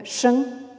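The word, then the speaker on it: sheng (聲): Et cette partie haute du caractère, vous la retrouvez Hein, euh, dans euh, le mot qui désigne le son, hein, euh, qui se prononce en chinois moderne sheng.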